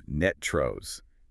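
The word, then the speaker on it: NTREIS: Locally, we pronounce that acronym NETROS, ending with a rust sound.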